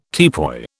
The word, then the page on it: teapoy: British English